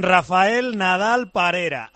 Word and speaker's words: Rafael Nadal Parera: Don Rafael Nadal Parera, así hay que decirlo, bien, entonando.